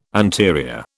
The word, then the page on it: anterior: British English